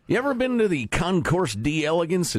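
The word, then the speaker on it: Concours d'Elegance: I've heard people lately trying to pronounce it properly in the French. Don't bother. The Concorde d'Elegance, or whatever that's. It's the Concourse d'Elegance.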